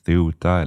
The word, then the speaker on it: Ceuta: Och jag vet att det här kan uttalas på lite olika sätt. Jag har sett Ceuta eller Sebta till exempel, men för konsekvensens skull kör jag på den här varianten.